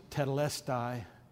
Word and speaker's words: tetelestai: And you remember Jesus shouted from the cross that blessed word, tetelestai, sometimes pronounced tetelestai, the Greek word, and it means it's finished.